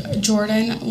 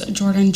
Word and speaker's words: Jordyn: My name is Jordan Lamb, but my married name is Jordan Johnson, and it's spelled J-O-R-D-Y-N.